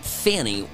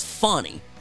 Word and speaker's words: Fani: So, Fani, I used to pronounce it Fani Willis, but I've been corrected. It's Fani Willis is the prosecutor down there in Fulton County, Georgia, who's brought this big case that was going to take down Donald Trump.